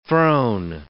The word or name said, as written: throne